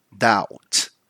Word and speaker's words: doubt: So mind the pronunciation here too, because this word, in this word, we don't pronounce the B. So we don't say doubt, we just say doubt, right?